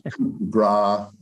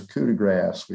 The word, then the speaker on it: Coup de Grace: But Coup de Grace, C O U P small DE, and then grass. Everybody mispronounces it. We tried to get a name but none of the bar owners would be able to pronounce. So it was almost coup de gras or, you know, they do whatever they could to butcher it, but it was coup de grass.